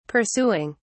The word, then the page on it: pursuing: British English